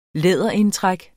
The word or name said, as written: læderindtræk